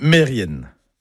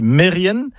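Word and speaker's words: Merien: Eh ben non, tout ça, c'est faux. C'est Mérienne, Kermérienne.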